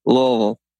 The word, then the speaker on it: Louisville: But uh, I do know how to say the city that you live in, Louisville.